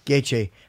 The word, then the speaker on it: Gaethje: Gaethje.